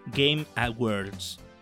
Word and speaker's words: Game Awards: Sí, los Game Awards. ¿Lo dije bien, Melina? – Aprobadísimo.